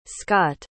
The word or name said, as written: scott